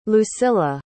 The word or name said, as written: lucilla